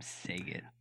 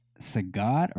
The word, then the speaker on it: Sagat: Was his name pronounced Sagat or Saget? – I feel like coming from the Jean-Claude Van Damme pronunciation, it would be Sagat.